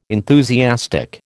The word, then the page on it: enthusiastic: American English